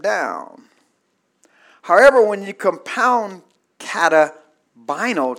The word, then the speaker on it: katabaino: Comes down is the Greek word or words, it's a compound word of katabino. Katabino, and it's spelled K-A-T-A-B-A-I-N-O.